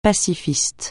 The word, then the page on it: pacifiste: francais